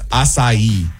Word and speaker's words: acai: A-C-A-I. It's acai.